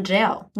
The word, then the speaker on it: gaol: The subject line is the one kid who DM'd you about Melbourne Jail, which a lot of European jails, they're spelled G-A-O-L. – Like Gaol. – Like Gaul. – Gaul. But it's pronounced jail.